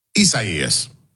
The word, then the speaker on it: Isaias: Isaias. – All right, Isaias.